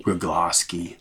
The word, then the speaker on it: Rugloski: Rugloski.